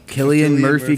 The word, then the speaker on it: Cillian: Killian Murphy, Killian Hayes.